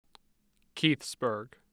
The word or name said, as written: Keithsburg